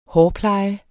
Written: hårpleje

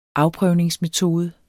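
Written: afprøvningsmetode

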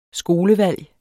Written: skolevalg

